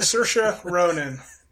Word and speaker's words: Saoirse Ronan: Saoirse Ronan. Yeah. – Because it doesn't look anything like it's spelled. No, it's some Irish or Scottish thing.